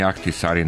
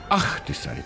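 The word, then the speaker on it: Ahtisaari: Kad je dolazio, Ahtisari, tako se izgovaralo. Ahtisari.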